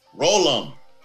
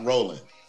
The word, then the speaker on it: Rollum: Instead of an N, you put an M. That's it. You got the first part right. You got to get the pronunciation part right. Rollum, not Rollin.